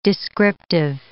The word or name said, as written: descriptive